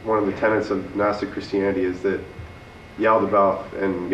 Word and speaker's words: Gnostic: In Gnostic Christianity, is that how you pronounce it? Gnostic? – Gnostic. Yeah.